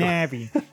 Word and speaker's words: Navi: And Navi flies away. – Just once. Just once for me. – Navi. – Thank you. – Navi.